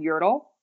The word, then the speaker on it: Yerdle: A lot of them are using a service called Yertle Recommerce, which is spelled Yerdle.